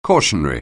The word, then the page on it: cautionary: anglais